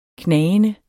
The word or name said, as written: knagende